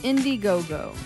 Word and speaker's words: Indiegogo: Is it Indiegogo or Indiegogo? – It's Indiegogo. – Indiegogo. – My bad. I said it wrong.